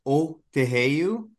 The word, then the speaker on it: Otoheyu: So it's, so we're pronouncing it Otoheyu. I think more of a traditional Plains Cree pronunciation would be Otoheyu, but we're kind of rounding the O a little bit. So Otoheyu.